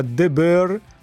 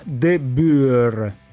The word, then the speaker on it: De Boer: Allora, ci sentono anche dalle Fiandre e ci correggono facendo i complimenti, ma non si pronuncia De Boer, ma De Boer. – De Boer.